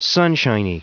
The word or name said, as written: sunshiny